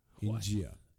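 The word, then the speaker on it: Inđija: For people who don't know, there's a town that apparently is spelled I N D I J A. – No, there's India. – The D is actually J. India.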